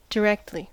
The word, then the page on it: directly: American English